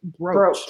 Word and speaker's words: brooch: So brooch has a silent O because it actually, or I guess, whatever. It's still pronounced brooch despite spelling. See, rhymes with coach.